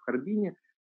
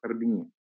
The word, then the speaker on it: Харбин: русские всегда говорят в Харбине, старый иммигрант всегда скажет в Харбине.